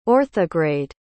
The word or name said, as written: orthograde